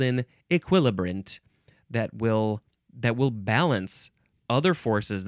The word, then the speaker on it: equilibrant: Okay, the last word is equilibrant. Equilibrant, you can also say E, still emphasizing the second syllable, equilibrant. You can also say equilibrant. Equilibrant, spelled E-Q-U-I-L-I-B-R-A-N-T.